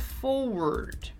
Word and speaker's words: forward: And also the pronunciation of forward with that very typical R sound, forward.